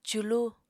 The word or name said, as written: culu